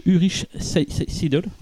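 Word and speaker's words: Ulrich Seidl: Avant de parler donc de, des réalisateurs et du film, on va parler de, de Ulrich Seidel.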